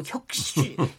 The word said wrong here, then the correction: 핵심: 혁신협약 기준이라고 자꾸 얘기를 했는데 핵심 협약의 비준입니다.